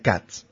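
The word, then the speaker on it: Kac: Hay apellidos derivados de iniciales hebreas como Katz o Kak, que en polaco se pronuncia Katz.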